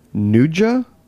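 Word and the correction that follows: nduja: Ndoya. – Ndoya. Nailed it.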